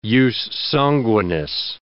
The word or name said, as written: jus sanguinis